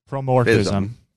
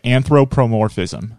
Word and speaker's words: anthropomorphism: Promorphism. – Anthropomorphism. – Yeah. – What am I saying? Sorry, you're breaking up. – You're saying it right. Anthropomorphism.